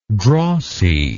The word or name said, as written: drossiness